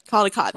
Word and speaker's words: Caldecott: Caldecott, thank you.